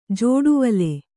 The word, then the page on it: ಜೋಡುವಲೆ: jōḍuvale